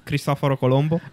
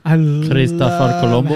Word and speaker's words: Cristoforo Colombo: Cristoforo Colombo. – Cristoforo Colombo. – How you have proper pronunciation of we call Christopher Columbus. – Say it sounds so sexy when you say Christopher Columbus.